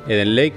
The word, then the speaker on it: Eden Lake: Y se llama Eden Lake, María, ¿os lo he dicho bien? Sí. Eden Lake que de hecho tiene como protagonista Michael Fassbender.